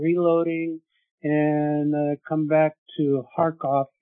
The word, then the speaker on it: Kharkov: And when I say Kharkov, that is how it is pronounced, Russian. Kharkiv is Ukrainian.